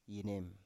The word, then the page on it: yenem: Biak